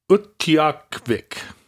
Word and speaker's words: Utqiagvik: So Barrow's Inupiat name is Utqiagvik. Utqiagvik. – Utqiagvik. The G is silent.